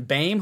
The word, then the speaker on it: Boehm: They were new left guard Evan Boehm, which I guess sounds like Bane, didn't know that's how you pronounce that last name,